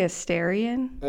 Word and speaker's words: Astarion: Let's settle on a pronunciation. – Okay, I don't know how you say it. I think it's Astrian. How do you want to say it? – I say Astarion. – Okay, I'll do Astarion.